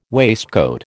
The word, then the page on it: waistcoat: American English